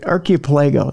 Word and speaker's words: archipelago: I'm trying to say that right. Archipelago. Does that sound right? – Yeah.